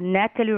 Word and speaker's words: Nataly: When you just write the word masks, M-A-S-K-S, Natalie Restokian, my name is spelled N-A-T-A-L-Y, Restokian, R-E-S-T-O-K-I-A-N.